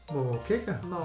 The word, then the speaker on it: moqueca: Moqueca.